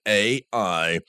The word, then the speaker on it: Ai: It's a single word, AI, and it's pronounced like each of its letters, as in A and I.